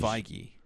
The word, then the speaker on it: Feige: Kevin Feige? Yeah, it's like beige. It's Feige.